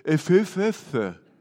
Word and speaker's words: Ephphatha: He looked up to heaven, and he sighed and said this Hebrew word that I can't pronounce, but everybody say it with me, Ephphatha. But you got to say it with like a deep groan.